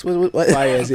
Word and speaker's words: Faiyaz: It Faiyaz. Is kind of weird. Yeah. – Faiyaz. – Faiyaz, yeah. – Faiyaz. – How do you spell that shit, too? – Faiyaz. – F-A-I-Y-A-Z.